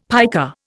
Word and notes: piker: Indian

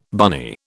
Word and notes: bunny: British English

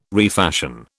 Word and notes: refashion: British English

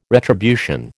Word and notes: retribution: American English

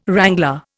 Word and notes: wrangler: Indian

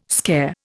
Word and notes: scare: Indian

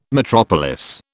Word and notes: metropolis: British English

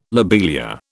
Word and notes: lobelia: British English

